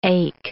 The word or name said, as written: ache